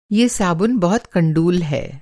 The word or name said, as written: कंडूल